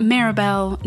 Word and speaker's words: Maribel: That's how you say my name. Not Maribel, not Mary Bell, not Marvel.